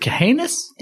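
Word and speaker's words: Cahanes: Is it Kahanis? Okay. – It's C-A-H-A-N-E-S. I've heard some people pronounce it Kahanis. I feel like Kahanis is, that's just how I would say it.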